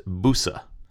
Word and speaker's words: bouse: In the 1600s, it was spelled B-O-U-Z-E, and was not used as a noun as far as we know. It was a variant of a Middle English verb spelled B-O-U-S-E, and probably pronounced boosa.